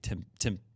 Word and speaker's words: Tempe: Tempe, sorry. – What's the difference? – Sorry, Tempe. – The pronunciation.